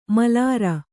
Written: ಮಾಲೇರು

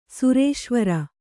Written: ಸುರೇಶ್ವರ

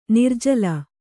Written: ನಿರ್ಜಲ

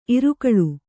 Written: ಇರಕಳಿ

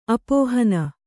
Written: ಅಪೋಹನ

